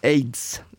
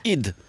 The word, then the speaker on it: Eid: Aids. – Nej. – Det uttalas id.